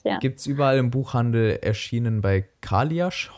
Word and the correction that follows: Kailash: hoffentlich richtig ausgesprochen. Kailash, genau.